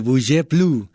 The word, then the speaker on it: bouge plus: Bouge, efectivamente, bouge plus. Porque en francés no se lee la us, la s, perdón, al final, no se lee plus.